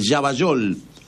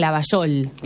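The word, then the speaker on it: Llavallol: Yavallol. Yavallol, pues se escribe Yavallol, no, Yavallol. ¿Cómo decís llave? ¿Llave o llave? – Llave, pero muchos le dicen Lavallol. – Bueno, pero es con doble L.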